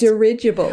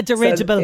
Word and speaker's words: dirigible: It's dirigible. – Ah, dirigible. – So it's one of those odd ones where it's the second part is the emphasis. So dirigible, as in rigid, you know, that kind of thing.